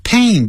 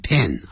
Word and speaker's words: pain: 扣人心弦好棒的景色你看山就是山水就是水哇还有那边唱所谓的这个我们讲的牧歌这个牧歌是怎么唱的牧童呢坐在背上就这样来哟到我们的山中来哟看到我在放牛哦你看你的声音真好听这样好好那这时候呢就是说 I feel a pain feel a pain feel 跟 a 产生连音 feel a pain pain 不要念成 pen 啊